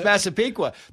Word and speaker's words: Massapequa: We mentioned Massapequa, and I had to ask three times, how do you pronounce this, Massapequa? It's Massapequa. The tribes have come out and said, listen, this is a sign of respect.